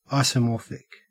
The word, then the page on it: isomorphic: Australian English